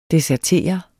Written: desertere

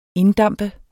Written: inddampe

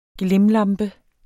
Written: glimlampe

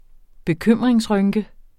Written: bekymringsrynke

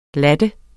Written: latte

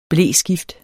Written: bleskift

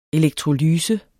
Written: elektrolyse

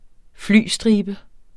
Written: flystribe

